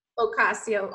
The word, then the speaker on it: Ocasio: Ocasio. O-C-A-S-I-O.